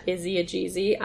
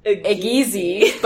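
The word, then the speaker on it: Izzie Gizi: My acting Instagram and personal Instagram is at Izzy Ajizi, IZZIEGIZI, sometimes pronounced by Sam Gash as Ageezy, but that is wrong. Look, I'm just so used to the way the G sounds in my last name.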